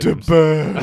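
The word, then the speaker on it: de Burgh: And is his last name pronounced De Berg? Is that correct? – Yes, you've got it exactly right. – Oh, good. – De Berg.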